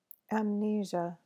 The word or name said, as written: amnesia